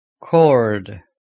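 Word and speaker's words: کرد: Since it is spelled K-U-R-D, should not be pronounced curd. It is cord.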